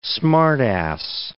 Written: smart-ass

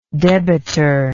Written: debitor

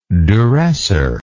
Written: duressor

